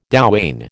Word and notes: douane: American English

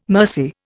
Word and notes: mercy: Indian

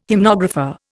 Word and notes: hymnographer: Indian

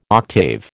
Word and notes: octave: American English

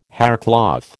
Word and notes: haircloth: American English

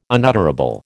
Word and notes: unutterable: American English

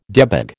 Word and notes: debag: American English

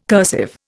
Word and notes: cursive: Indian

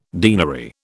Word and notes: deanery: British English

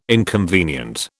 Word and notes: inconvenient: British English

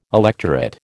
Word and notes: electorate: American English

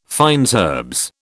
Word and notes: fines herbes: British English

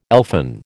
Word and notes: elfin: American English

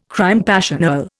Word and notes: crime passionnel: Indian

